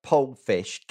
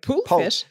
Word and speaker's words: Pollfish: P-O-L-L-F-I-S-H. – Oh, Pollfish. Okay, I was like, Poolfish? Sorry. – Poolfish. Yeah, no, that's my West Country English accent. Sorry about that.